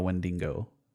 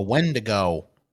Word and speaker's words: Wendigo: Like they do it with a supernatural twist of the father became a Wendigo. – Wendigo, bro. It's a Wendigo.